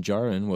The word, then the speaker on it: Djarin: and the D in Djarin was a silent D.